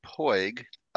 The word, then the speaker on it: Puig: My last name is pronounced Poig. – There it is. – Yep. It's spelled P-U-I-G, which, of course, in light of the fact that Yasiel Puig plays in the National League these days, whenever people see that name, they immediately think they know how to pronounce it, and I have never pronounced it that way myself.